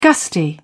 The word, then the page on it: gusty: British English